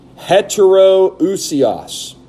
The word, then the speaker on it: heteroousios: First, heterousios. I'll spell that for you. H-E-T-E-R-O-O-U-S-I-O-S.